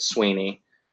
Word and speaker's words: Sweeney: It's pronounced in Gaelic, it's pronounced Sivna. But, you know, so I get a lot of comments on my videos from angry Irishmen telling me that I'm pronouncing my own name incorrectly. And, it was when it was moved over to England, the English couldn't say Irish names very well, so they just said Sweeney, and that pronunciation has stuck.